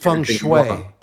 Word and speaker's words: feng shui: It's pronounced feng shui.